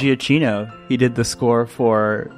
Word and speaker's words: Giacchino: Giacchino. – That's the way you pronounce it.